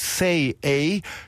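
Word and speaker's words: Sei A: Er spricht sich tatsächlich Say A, schreibt sich aber, und das sollte ich vielleicht dazu sagen, S-E-I und dann Buchstabe A.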